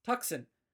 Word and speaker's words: Tucson: But, I mean, there's a city down south that's spelled, you know, T-U-C-S-O-N, which, for most of you listeners, spells Tucson, mm-hmm. but is actually pronounced Tucson, because it's Spanish.